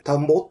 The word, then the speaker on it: 田んぼ: 新潟の方って、親戚とか、兄弟とか、みんな米育ててるところなんで、あ、ちなみに、新潟ではですね、田んぼっていうらしいですよね。このイントネーション、田んぼ。